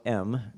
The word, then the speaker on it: Hem: it's in a town called M. It's written H-E-M, but it's pronounced like the letter M.